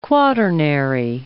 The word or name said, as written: quaternary